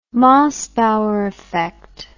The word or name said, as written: mossbauer effect